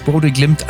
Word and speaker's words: Bodø Glimt: Ja, wie spricht man dieses Bodø Glimt eigentlich aus?